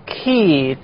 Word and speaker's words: key: Key 这个单词后面呢是 E 的音，而字母 K 后面是 A 的音。